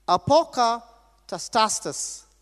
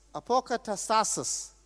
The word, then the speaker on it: apokatastasis: The word restoration is a word, and I'm going to try to pronounce it. Are you ready? Apokatastasis. Apokatastasis. APOKATASTASIS. And you may have another way of pronouncing it, but I'm pronouncing it from the original Greek.